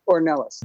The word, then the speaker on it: Ornelas: Ornelas.